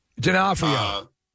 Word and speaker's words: D'Onofrio: D'Onofrio.